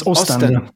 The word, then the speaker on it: Ostend: Usten, am I saying that right? Usten? – Ostend. Yeah. – Okay. Ostend. Okay.